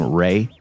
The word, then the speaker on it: Ralph: I think you actually pronounce his name Ray. – Ray Fiennes? – Yeah, but it's spelled Ralph.